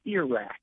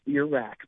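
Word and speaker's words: Iraq: And he had me do weather and a newscast, and he said, "It's ear rack," and he pointed to his ear, and because this was in the 90s, and he said, "It's ear rack, pronounce it that way."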